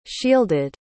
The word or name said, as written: shielded